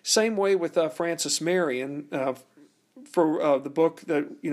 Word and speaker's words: Marion: Interesting enough, Francis Marion's family, being that they were French Huguenots, their last name was originally pronounced Marion. But of course, when they came to America, it was anglicized and we get Marion. Well, it turns out that Revere in French was pronounced Rivoire, R-I-V-O-I-R-E, Rivoire.